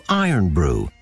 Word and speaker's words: Irn-Bru: So the name was phonetically changed to Iron Brew, spelled I-R-N-B-R-U.